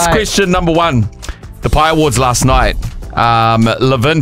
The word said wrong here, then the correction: Levain: Bakery in Blockhouse Bay. I think I said that wrong. I think it's Levain Bakery in Blockhouse Bay, Auckland.